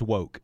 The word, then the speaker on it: Wouk: First one's an author of the Caine Mutiny, Herman W-O-U-K. – I know him. But now, how do you pronounce it? – I would say Herman Wouk, but I think it's wrong. – That's what I said. – That is incorrect. It's woke.